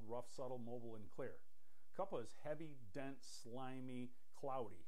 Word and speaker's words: kapha: If you see a bright white, like the color of the paper, it's more kapha, spelled K-A-P-H-A. Some people say kapha. But when I was in India, I had the pronunciation police after me, and they kept harping on me that the H is silent, so it's kapha.